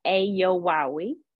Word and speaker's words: Eyiowuawi: I am Aminah E. Yowawi. And that's spelled E-Y-I-O-W-U-A-W-I.